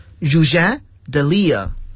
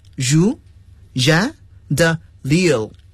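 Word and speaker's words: Rouget de Lisle: 好了，这个词呢是典型的法语词，我们要按法语来发音了。 Roger de Lille。 Roger de Lille。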